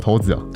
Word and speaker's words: 骰子: 那骰骰子的话是要念头头子哦。